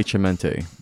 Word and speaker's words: Cemente: Jay Cemente. – Jay Cemente, which rhymes with cemente.